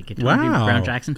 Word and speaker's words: Ketanji Brown Jackson: It says, Supreme Court frontrunner, this was at the time frontrunner, when Ketanji Brown Jackson, and I can actually pronounce the name correctly, Ketanji Brown Jackson.